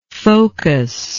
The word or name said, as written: focuser